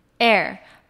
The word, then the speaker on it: heir: 那它的发音呢更特别，它是只有 air 的音。 air 不是 hair 哦，是 air，它只有 air 的音，h 不发音。那我们可以说，A lot of people suspected that he is going to be the heir of this enterprise。